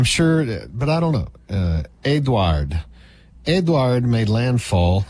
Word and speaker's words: Edouard: This is like Edouard, if it's in French, you don't even pronounce the D. Edouard, who makes these stupid names for tropical storms? – I don't know.